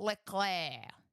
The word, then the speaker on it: Leclerc: And then Ferrari came third with your boy Charles Leclerc. They came third in the Manufacturers' Championship. – At least say it right. Charles Leclerc.